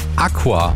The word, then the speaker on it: Acua: Das sagt Sänger und Gitarrist von unserer heutigen Heimatkultband Aqua. A-C-U-A in Großbuchstaben geschrieben, aber halt ausgesprochen wie das lateinische Wort für Wasser.